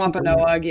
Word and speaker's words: Wampanoag: Wampanoag, yeah.